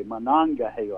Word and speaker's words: Monongahela: the 12 horses, Monongahela. – Monongahela. – Monongahela. Okay, Monongahela. I was getting the syllables in the wrong order.